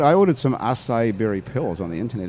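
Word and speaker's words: açaí: I am drinking a ginger acai berry martini. – Wow. – Is that how fancy. – You pronounce it? Acai. – That's what I was told. – Yes, well, it is.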